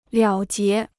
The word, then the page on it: 了结: liǎo jié